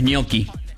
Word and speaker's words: gnocchi: Que la pronunciación de gnocchi en italiano es gnocchi.